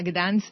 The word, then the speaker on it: Gdańsk: Buenos días, gracias por poder compartir las informaciones sobre Gdańsk con vuestros oyentes. – Bueno, pregunta obligada, si nos vamos, yo no lo digo tan bien como tú, si nos vamos a Gdańsk ahora en julio, ¿qué ropa tenemos que echar?